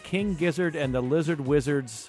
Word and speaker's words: King Gizzard and the Lizard Wizard: King Gizzard and the Lizard Wizard's Dreams? – Yes, perfect.